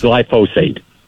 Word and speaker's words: glyphosate: But but vinegar is actually more toxic than glyphosate is, which is pronounced glyphosate. – Glyphosate. Yeah. Okay, so the guy glyphosate. I've been mispronouncing it. Everybody else has been pronouncing it correctly.